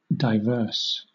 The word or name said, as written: divers